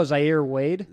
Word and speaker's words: Zaire Wade: Oh, Zaire Wade.